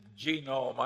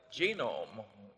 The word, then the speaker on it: Genome: Well, I mentioned that one book, Genome. I have a hell of a time putting the accent on the first syllable.